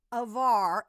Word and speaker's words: Hvar: Avar, and then they show it. It's spelled like HVAC, pronounced like Havarti.